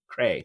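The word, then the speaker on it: Krei: Was his last name said Cree? I'm trying to remember. – Cray. – I watched this yesterday. Cray. – It's Cray.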